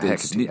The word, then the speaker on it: Hecate: Now, what do you think for Hecate or Hecate? – Oh, yeah, Hecate. I mispronounced that.